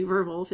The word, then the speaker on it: Werwolf: In Nazi Germany, they used werewolf, which is actually werwolf is how you say it in German, as the code name of one of Hitler's headquarters.